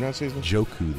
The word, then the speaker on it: Njoku: Njoku. The N is silent.